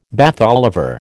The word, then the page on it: Bath Oliver: American English